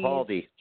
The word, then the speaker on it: Capaldi: Capaldi.